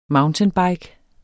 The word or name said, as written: mountainbike